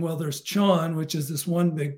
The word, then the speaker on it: Chan: But it's from Gillian or Jillian, and it said, are the words Chan and Zen interchangeable? – Oh. The relation of them is this. There's a Chinese ideogram, and it in China, you pronounce it Chan.